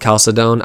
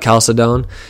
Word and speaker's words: Chalcedon: and the Council of Chalcedon. I I think I pronounced that wrong, but but Chalcedon, and that reaffirmed that Christ is one divine person with a fully human nature and a fully divine nature.